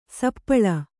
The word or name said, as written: ಸಪ್ಪಳು